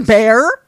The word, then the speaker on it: Bexar: So she eventually got hired to work in the pediatric intensive care unit at, and it's spelled B-E-X-A-R, but I know from watching Lockup, it's pronounced bear.